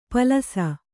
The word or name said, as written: ಪಲಸ